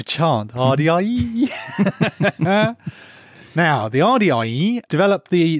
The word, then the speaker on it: Ardiaei: It's spelt A R D I a E I. and I think it's Ardiaei.